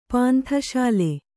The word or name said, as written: ಪಾಂಥಶಾಲೆ